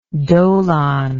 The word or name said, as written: dolon